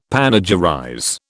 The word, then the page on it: panegyrize: British English